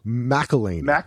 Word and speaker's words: McElhaney: How do you pronounce his last name? – McElhaney. – McElhaney.